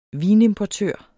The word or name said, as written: vinimportør